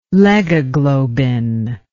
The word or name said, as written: legoglobin